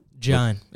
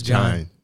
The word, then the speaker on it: Jagne: And so, Jagne, it's Jagne. – That's right. I'm gonna get your name right. – It's all right. Eventually. It's like nobody gets it. It's like Vine with a J.